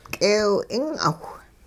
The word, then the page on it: k'eew-'ing'awh: hupa